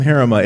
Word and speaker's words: Harima: But if you ever wanted to know how to pronounce my last name, Harima, it's a lot like heretic.